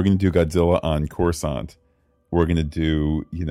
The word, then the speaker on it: Coruscant: Coruscant, which is what I wanted to say, but I'm so gun-shy.